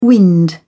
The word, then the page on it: wind: British English